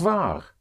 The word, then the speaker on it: wahr: War also means true and that is spelled W-A-H-R, but it has the same sound, same pronunciation as was.